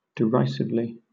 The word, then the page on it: derisively: British English